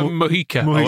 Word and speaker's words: Mujica: We had Majuka. – Who? – If that's how you pronounce his name. – Rafa Mujica. – Yes, Mujica, him.